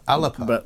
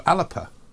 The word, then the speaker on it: ALBA: See, the Gaelic pronunciation is ALBA. – ALBA. – But the English or Scots pronunciation is ALBA. It's like Paris and Paris. – Right. So ALBA.